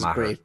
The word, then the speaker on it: Mather: How do you pronounce it? – Mather's? – Name? Matter. – Like Marshall. – It's just Matter. I think it's singular. – Oh, okay. I thought it was like Marshall Mathers.